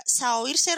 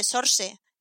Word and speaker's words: Saoirse: Creo que se dice Sorse en, inglés.